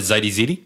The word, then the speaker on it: Zite: It's Z-I-T-E. – Like so. Yammer, is it Zitey ZD? – No, I think it's Zite. – Okay. – But I could be saying that wrong. – Z-Y. Really cool. – Z-I-T-E.